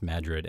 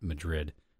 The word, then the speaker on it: Madrid: The New Madrid Fault is, and it's, as far as I know, it is pronounced Madrid and not Madrid for some stupid reason.